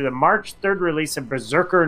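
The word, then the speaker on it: BRZRKR: And now the actor will produce and star in a live-action Berserker. It's so weird how it's spelled. It's B-R-Z-R-K-R. So it's like, I know it's Berserker, but it's hard to, like, read it that way.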